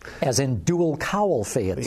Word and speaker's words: Phaeton: I got to mention, Peter's rant this week on his website was all about the Volkswagen Phaeton, and now I'm being criticized for pronouncing it Phaeton. It's Phaeton.